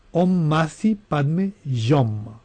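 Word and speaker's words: Om Mani Padme Hum: Par exemple, le mantra Om Mani Padme Jom qui se prononce Om Masi Padme Jom.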